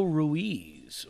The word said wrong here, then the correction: Ruiz: Or no, sorry, Angelo Reese. – Yeah, it's kind Reese. It's pronounced Reese. – Yeah, it's spelled Ruiz,